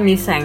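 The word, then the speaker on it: Miseng (미생): ada judulnya namanya Misaeng tulisannya Misaeng bacanya Miseng hmm.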